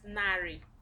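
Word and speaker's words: narị: 100 is nari.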